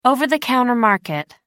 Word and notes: over-the-counter market: American English

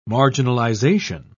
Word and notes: marginalisation: British English

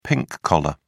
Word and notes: pink-collar: British English

